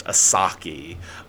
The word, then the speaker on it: 4s4ki: Um, but anyway, next one, similar kind of vibes, uh, is Ring Ring You Kill Me by, I think it's pronounced Asaki. Um, it's spelled 4S4KI.